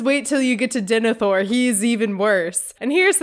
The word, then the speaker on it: Denethor: I will say, so I know that Denethor, is that how you say his name? – Yeah.